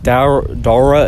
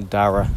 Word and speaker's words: Dara: You know my like Dara is not how you pronounce my name. – How do you pronounce it? – It's actually Dara. – Dara. Dara? – But no one can say like the ah.